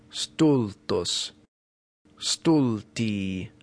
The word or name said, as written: stultus stulti